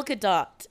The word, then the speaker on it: Gadot: Gail Godot decided to sing that song for us. – Godot? I'll kill you. – No, that's how you say her name.